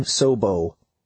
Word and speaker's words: Sobo: "I'm Sobo." The word rhymes with hobo, and it means southbound.